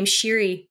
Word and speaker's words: Sheeri: The first place to look would be the username Sheeri, almost wherever you are. That's my username on GitHub. It's my username on Twitter. – And spell it? – Sheeri.